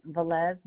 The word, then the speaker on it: Velez: Marjorie Velez, V-E-L-E-Z, Erickson, E-R-I-C-K-S-O-N.